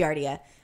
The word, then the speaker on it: Giardia: Giardia. I know, because from Colorado. – Giardia? – That's how I've always heard it pronounced, okay. Giardia.